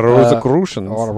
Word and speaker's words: Rosicrucians: So the secret society, like the Rosicrucians, is that how you pronounce it? – Yeah. Rosicrucians.